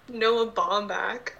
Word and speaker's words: Noah Baumbach: How do you say it? – Noah Baumbach. – Okay.